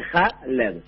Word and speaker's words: Khaled: Si lo ves escrito te complica más porque se escribe con K, la KH en el vocabulario árabe es la letra ja, entonces viene por eso que se escribe Khaled, pero se dice ja, LED.